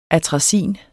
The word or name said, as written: ATRAZIN